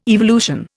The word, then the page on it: evolution: Indian